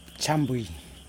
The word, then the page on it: tsambwi: Yokoim